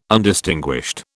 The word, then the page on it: Undistinguished: British English